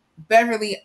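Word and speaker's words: Beverley: Beverly Andre _ Beverly with an L-E-Y. I had to add that to my Twitter profile because they're still spelling my name wrong. So, giggles, but true. Beverly with an L-E-Y.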